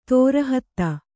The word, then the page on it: ತಿರೋಹಿತ: tirōhita